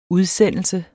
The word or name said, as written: udsendelse